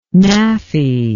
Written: naafi

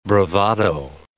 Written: bravado

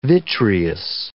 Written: vitreous

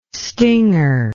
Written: stinger

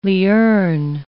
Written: lierne

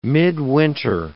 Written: midwinter

